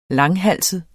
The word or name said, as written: langhalset